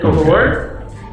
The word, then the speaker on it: Velours: The concept is actually, I'm using the French spelling and pronunciation, so that S is actually silent. So it's just Velours, way you say it in English.